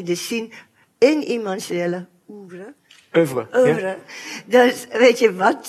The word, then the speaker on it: oeuvre: Ook binnen iemands eh, oeuvre kan nooit dat woord uitspreken. – Oeuvre, oeuvre. – Oeuvre. – Oeuvre, ja.